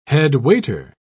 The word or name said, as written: headwaiter